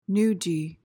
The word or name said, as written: noodgy